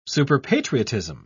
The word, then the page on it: superpatriotism: British English